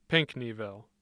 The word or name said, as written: pinckneyville